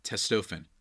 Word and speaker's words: Testofen: Testofen, I should say, actually. I pronounced it wrong.